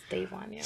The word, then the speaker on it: Davonya: DAVONYA. – A lot of people say DAVONYA, but it's DAVONYA.